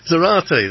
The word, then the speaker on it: Zarate: And we've also got Patricia Zartari. You say it like karate. I'm good. Good morning. – Good morning. – I'm practicing my Spanish here. So, Zarate, there we go.